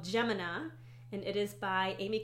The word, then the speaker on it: Gemina: Gemina Part 2 from the Illuminae Files by Amy Kaufman and Jay Kristoff.